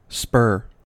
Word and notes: spur: American English

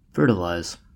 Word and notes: fertilize: American English

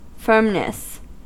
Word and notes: firmness: American English

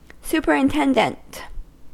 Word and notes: superintendent: American English

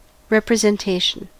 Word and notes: representation: American English

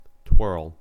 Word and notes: twirl: American English

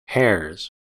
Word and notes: hares: American English

